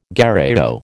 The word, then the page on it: gherao: American English